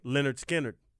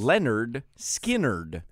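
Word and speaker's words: Lynyrd Skynyrd: Leonard Skinner. It's how it sounds. – Leonard Skinner.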